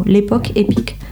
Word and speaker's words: Lepok Epik: Le Facebook, c'est l'époque épique. Donc, euh, comme ça se prononce en créole, L-E-P-O-K, E-P-I-K, euh, en, deux, en deux mots, l'époque épique.